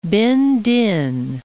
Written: Binh Dinh

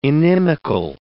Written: inimical

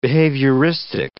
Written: behavioristic

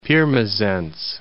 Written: Pirmasens

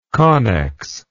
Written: connex

